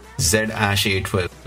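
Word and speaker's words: X Æ A-12: So the AE thing is Ash. ZASH-A12, and the rest are his children: Nevada Alexander, Damien, Griffin, Xavier, Saxon, Kai, and now ZASH-A12.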